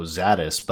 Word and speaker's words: Xadus: Yeah, his character's name is Zadius. Is that how you pronounce that? X-A-D-U-S, and, I was going to go Zadis, but Zadis sounds fine too.